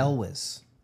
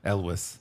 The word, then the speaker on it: Elwes: Carrie, how do you pronounce his last name? – Elwes. – Oh, yeah. Elwes. – Elwes.